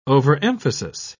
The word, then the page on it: overemphasis: British English